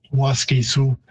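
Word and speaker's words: Waskesiu: So Waskisu, Waskisu again in typical English pattern, if there is a stress on the third last syllable, like Canada, you tend to reduce the second syllable. So Waskisu, it would be a longer vowel in Cree, even though the stress is on the first syllable.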